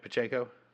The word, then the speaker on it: Pacheco: But Pacheco is, am I saying that right? Pacheco? – Yeah, I believe so.